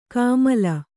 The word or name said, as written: ಕಾಮಿಲೆ